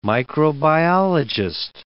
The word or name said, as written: microbiologist